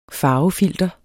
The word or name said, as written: farvefilter